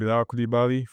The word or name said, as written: Bilal Coulibaly